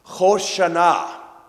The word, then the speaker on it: Hoshana: Hosanna, or as it is pronounced in Hebrew, Hoshana.